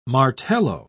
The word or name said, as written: martello